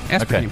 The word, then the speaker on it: aspartame: How do you pronounce this thing? Aspartame? An artificial sweetener? – Yeah, aspartame. Okay.